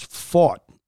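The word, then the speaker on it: Pfaadt: I do believe it's pronounced as fought. Okay. How the hell? P-F-A-A-A-A-D-A-A-D-A-D-P-H-A is pronounced fought.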